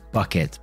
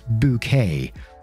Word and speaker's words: Bucket: And the main character's name is called Hyacinth Bucket, but she insists it's pronounced Bouquet.